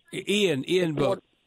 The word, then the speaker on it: Ian Book: Is it Ivan Book? – Ian Book.